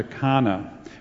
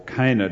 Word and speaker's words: Cana: He's been back up to Galilee, into Samaria, and now he's back close to Cana, or Cana, depending on which university you went to and how you pronounce it.